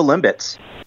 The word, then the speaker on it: Limbits: What is No Limbits? – Yeah, so uh, the Bee is silent. We're actually no limbits, and it okay, is an adaptive great.